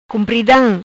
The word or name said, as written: Cumpridam